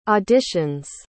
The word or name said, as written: auditions